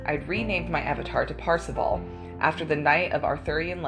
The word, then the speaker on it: Parzival: But in the book, it's spelled Parzival, so Parsival is what I'm going with.